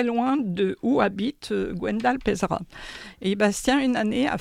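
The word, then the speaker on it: Peizerat: Ça, c'est peut-être le mal connu, malheureusement, et Gwendal Pézeria. – Pézera. – Qu'est-ce que je dis ? Pézeria. – Non, c'est Pézera. – Non, c'est Pézera, oui, il me semble.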